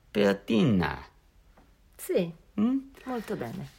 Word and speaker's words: piadina: How would you pronounce that? Piadina? – Piadina.